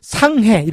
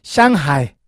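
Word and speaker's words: 샹하이 (上海): 그래서 중국도 이제 조금 여러분 좀 조금 아는 척 중국을 좀 아는 척 하시려면은 상해 이렇게 하지 마시고 샹하이 노래도 있죠. 샹하이 샹하이 뭐 이렇게 하는 노래도 있는데 어 샹하이 예?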